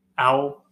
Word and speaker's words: Owel: Owl. O-W-E-L.